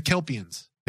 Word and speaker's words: Kelpians: The Kelpians.